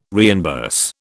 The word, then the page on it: reimburse: British English